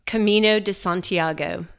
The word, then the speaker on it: Camino de Santiago: Camino de Santiago.